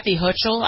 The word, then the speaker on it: Hochul: Letitia James and Kathy Hochul, I believe is how you say her name.